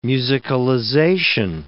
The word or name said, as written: musicalization